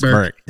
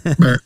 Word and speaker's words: Berwick: Yeah, pronounce it right. It's Berwick. – Berwick.